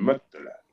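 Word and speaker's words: Mottola: Mm, perdona, vamos a pronunciarlo bien. Motola.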